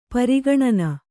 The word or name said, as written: ಪರಿಗಣನೆ